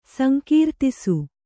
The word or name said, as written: ಸಂಕೀರ್ತಿಸು